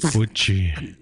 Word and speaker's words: Uchi: Uchi! – Which we you don't say it like that. – How do you say it? – Uchi. – Uchi.